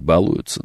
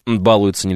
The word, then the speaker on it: балуются: Кстати, правильно говорить, балуются, да, такое ударение уродское абсолютно, ну как есть, балуются нельзя говорить.